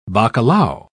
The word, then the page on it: bacalao: British English